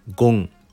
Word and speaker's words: 金: 現在では曜日に使われる漢字を書きますが読み方は木、火、土、ゴン、水と読みます。